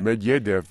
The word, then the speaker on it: Medvedev: Medvedev, but then I worked in Moscow.